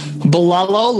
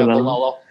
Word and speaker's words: Bilello: Bilello, like yellow, hello, mellow, jello, fellow. Bilello? Yeah, Bilello.